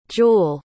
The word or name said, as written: jule